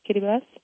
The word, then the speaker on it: Kiribati: I'm leaving to go to an island in the South Pacific called Kiribati, or Kiribati. It's pronounced Kiribati.